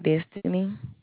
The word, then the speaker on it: Destiny: My name is Destiny. – Destiny, D-E-N. – D-E. – Spell it for me. – S-T-I-N-Y.